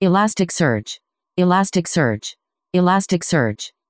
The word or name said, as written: elasticsearch